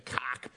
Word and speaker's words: Koch: I think it's the same people running it, and the Koch brothers, of course, you know, running everything. No, that's how they want it pronounced. It rhymes with sock.